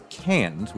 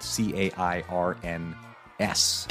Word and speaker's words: Cairns: And and so the city you fly into out of Sydney is you go to Cairns, which is spelled C A I R N S. That's how it's pronounced. Wow. Yeah, it's pronounced Cairns.